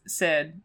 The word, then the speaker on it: Syd: Also, my nickname which this is really easy uh, because it's Syd, so rhymes with squid.